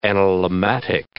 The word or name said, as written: analemmatic